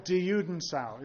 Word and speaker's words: die Judensau: There are over 30 different examples of die Judensau. Is that how you pronounce it? Mm-hmm. Yeah.